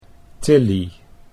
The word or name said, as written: Tillie